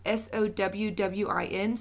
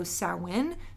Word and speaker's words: Samhain: So it's spelled Samhain, but the Gaelic pronunciation is Sowwin, so Samhain.